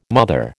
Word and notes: mother: American English

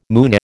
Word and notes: Moonie: American English

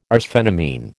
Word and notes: arsphenamine: American English

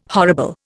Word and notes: horrible: Indian

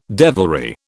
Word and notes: devilry: British English

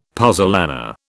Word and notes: puzzolana: British English

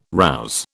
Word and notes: rouse: British English

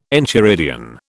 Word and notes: enchiridion: British English

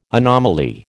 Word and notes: anomaly: American English

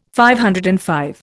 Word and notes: DV: Indian

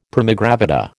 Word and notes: primigravida: American English

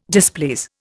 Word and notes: displease: Indian

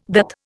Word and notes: DAT: Indian

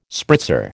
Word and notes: spritzer: American English

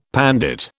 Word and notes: pandit: British English

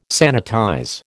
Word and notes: sanitize: American English

